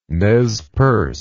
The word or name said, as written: Nez Perce